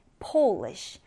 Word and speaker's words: Polish: polish. 자, 이 단어는 일단 대문자 P로 시작을 하고요. 그리고 발음도 약간 달라요. O 발음입니다.